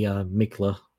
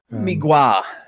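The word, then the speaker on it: Mgła: Migwa. – Is that how you pronounce it? – But, yeah, the L with a little slash is like a W sound.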